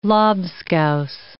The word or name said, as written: lobscouse